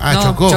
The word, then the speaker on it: chocó: choco, bajando por ahí. – Choco, no, chocó. – Chocó, con acento en la O, ¿ya?